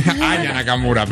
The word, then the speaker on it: Aya Nakamura: Tampoco se sabe si en la ceremonia inaugural va a cantar Ana Nakamura, que es la Naya Nakamura, ¿no? Lo he dicho bien. Aya Nakamura, perdón.